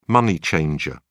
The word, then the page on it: money changer: British English